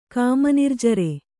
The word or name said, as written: ಕಾಮನಿರ್ಜರೆ